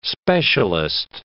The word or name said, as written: specialist